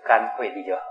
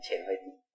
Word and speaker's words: 干慧地: 大智论的解释呢，他说这初地的菩萨他只有智慧，可是没有禅定水，因为禅定水是干干的嘛、哦、所以这个不用念破音啊，直接就干慧地就好、哦、不要念成浅慧地，干慧地。